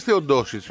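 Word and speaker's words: Teodosić: Γιατί μου λες Τεοντόσιτς. – Αφού έτσι το προφέρει.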